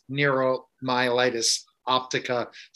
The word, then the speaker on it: neuromyelitis optica: And so I'm going to ask Dr. Williams, what is neuromyelitis optica spectrum disorder? Good thing for early in the morning to ask that question. – Absolutely. So that pronunciation was absolutely perfect.